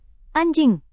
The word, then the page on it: anjing: indonesian